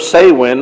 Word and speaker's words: Samhain: It's spelled Samhain, but it's pronounced Samhain, or summer's end.